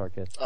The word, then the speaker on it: Arceus: Arceus. No, it's pronounced Arceus.